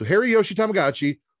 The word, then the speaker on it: Hiroshi Tanahashi: I was mispronouncing Tanahashi's name. And that's where it was offensive. So I've now changed it to Hiroshi Tanahashi instead of Hiroshima Tanahashi. So Hiroshi Tanahashi will be taking on Herky Jerky Gato.